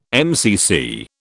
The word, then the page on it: MCC: British English